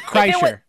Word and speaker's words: Kreischer: But Burt Kreischer.